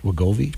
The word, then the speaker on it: Wegovy: It'll be a competitor to, is it Wegovy? Is that how you say it? Wegovy? – Govy. – Yeah.